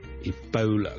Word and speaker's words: Ebola: 跟最近咧，如果大家有睇国际新闻咧，都应该会留意到 Ebola 呢个伊波拉病毒咧，喺某啲地方肆虐噶。 Ebola 伊波拉病毒咧，我哋虽然广东话我哋译做伊。波拉波啦，我哋系 all 一个发音，但如果英文咧，我哋通常咧系读 Ebola 嘅 Ebola，煲煲，好似弓箭嘅弓嗰个煲，OK，咁所以咧唔系 Ebola， but Ebola， Ebola。